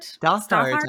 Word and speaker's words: Stothart: And that, just to give credit, the conductor of the MGM Studio Orchestra was Herbert Stout Hart? Am I saying that right? Herbert Stothart? – I know, it's like Stothart? Stothart? – Stothart, I think. – Okay, yeah, thank you.